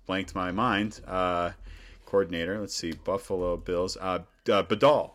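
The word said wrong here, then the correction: Daboll: I'm saying his name wrong. I'm sorry, Dable, Brian Dable, excellent offensive coordinator.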